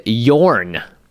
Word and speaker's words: Jorn: All right, why did you choose Yorn? Not Jorn, apparently, by the way. That was a note we had in the comments. – Yeah, I mean, yes, let's start there. I clearly was mispronouncing, everyone was mispronouncing the name of Jorn, it's Yorn, the entire episode.